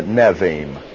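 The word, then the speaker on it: Nevi'im: And again, the proper Hebrew word for that is the Nevim. N-E-B-H, pronounced Nev, and then the Im, plural, prophets.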